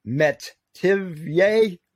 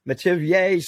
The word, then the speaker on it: Metivier: It's called magnetic memory methods, and it's by Dr. PhD Anthony, and it's pronounced Metivier, Metivier, spelt Metivier, if you will.